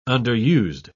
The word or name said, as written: underused